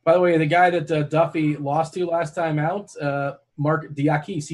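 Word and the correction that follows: Diakiese: he's also – I don't know if I pronounced that correctly. – Yeah, Diakese. – Oh, Diakese. Oh, I was way off.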